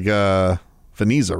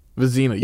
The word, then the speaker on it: Vezina: Sorry to And if I put the spelling up, 9 out of 10 people pronounce it wrong. – Yeah, I know. It looks like Veniza, right? – Vezina? – Vezina, yeah.